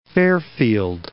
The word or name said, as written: Fairfield